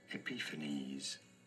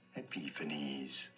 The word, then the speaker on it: Epiphanes: There are two different ways of saying the first name, which are as Antiochus, Antiochus, or Antiochus, and then Epiphanes, Epiphanes.